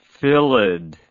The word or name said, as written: phyllid